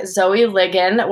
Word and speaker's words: Ligon: And this week, I'm welcoming back Zoë Ligon, which I still remember from your first bio is like friggin', right?